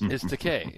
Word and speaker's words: Takei: It's Takei. It's rhymes with gay, so that's what it's awesome.